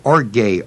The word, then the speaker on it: orge: And the word for wrath is the Greek word orge, O-R-G-E, long E.